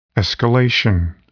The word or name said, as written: escalation